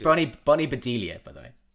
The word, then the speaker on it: Bedelia: But yeah, Bonnie Bedelia.